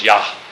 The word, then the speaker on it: Yah: Capital L-O-R-D is Yahweh. And actually in Truthbase this week, one of the Psalms, it says his name is Yah. And that's the correct pronunciation.